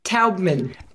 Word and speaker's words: Taubman: Tubman approach. So let's begin there with you explaining what the Tubman, I, I should know how to pronounce that. How do I pronounce that? Taubman.